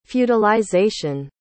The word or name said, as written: feudalization